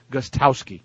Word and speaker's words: Gostkowski: Gostkowski, I should say. You don't pronounce the first K. Gostkowski.